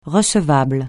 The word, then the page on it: recevable: francais